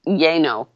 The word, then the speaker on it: Llano: Well, and, and yeah, in Texas, L L A N O is Yano. No, it's Yano.